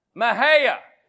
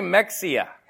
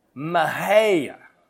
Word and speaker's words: Mexia: Everyone who lives in, how do you pronounce where I'm staying? Mahaya. I want to say Mexia. Mahaya.